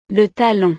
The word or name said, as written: le talon